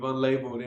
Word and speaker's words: UNLBLD: Yeah, that's our collective, Unlabeled, and it's spelled UNLBLD,